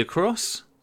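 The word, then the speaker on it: Acros: See, I say Fuji Akros. Is that how you say it, Rach? – It is, but maybe that's your influence.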